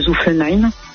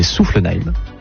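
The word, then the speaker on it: Soufflenheim: Alors, si je peux me permettre de sonner une toute petite rectification sur la prononciation, c'est Zuffenheim.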